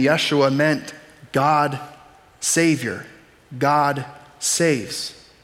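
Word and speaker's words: Yeshua (ישוע): Jesus' name, they probably didn't say it like Jesus. It sounded more like Yeshua.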